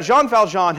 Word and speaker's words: Jean Valjean: But you got Jean Valjean, who's this prisoner who's been locked in prison for however many years of his life.